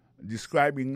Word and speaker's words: describing: Ça nous ka wè là, ça nous constate là, nous ka constate que li a prononcé mot de describing. Accent tombé sous deuxième syllabe là.